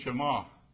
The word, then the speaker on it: Shema: And it's sometimes pronounced Shema, but it's really Shema.